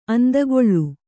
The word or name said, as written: ಆಂದುಗಲ್